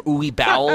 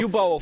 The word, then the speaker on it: Uwe Boll: Uwe Bowl or something. – Uwe Bowl. – Uwe Boll. – So Uwe Boll. – He's like, you fucking idiots, the name is pronounced Uwe Boll.